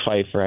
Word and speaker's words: Pfeiffer: My last name is pronounced Pfeiffer. I know it's a very unique way to spell it.